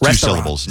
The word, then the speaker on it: restaurant: It's restaurant. It's two syllables, no.